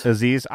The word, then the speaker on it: Aziz: Did you listen to the Aniz Ansari? How you say his name? – Aziz.